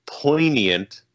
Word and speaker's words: poignant: The word was poignant. I pronounced it wrong. I pronounced the G because there's a G in it.